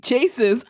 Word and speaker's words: Chasez: It's spelled Chasez. Chases. That's how it's spelled. It's literally spelled Chase with a Z. Chases.